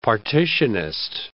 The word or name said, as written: partitionist